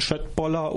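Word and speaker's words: köttbullar: Ich weiß nicht, inwieweit ihr in der schwedischen Kultur, also jetzt mal von Schöttboller und Billigregal abgesehen, bewandert seid. – Ja, immerhin kannst du Schöttboller schon mal richtig aussprechen. Das können ja die meisten IKEA-Kunden nicht.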